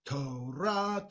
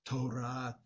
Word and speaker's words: Torah: And I was taught that the proper way to sing it is Torah, Torah, Torah, va'avodah. Right, with the accent, as I said, on the final syllable rather than on the penultimate syllable.